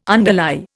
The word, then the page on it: underlie: Indian